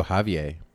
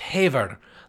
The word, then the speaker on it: Haver: Radio Haver is how it would be spelled, but it would be pronounced Haver, like the Proclaimers song where they go, "When I haver, well, I know I'm going to be the man that's havering to you."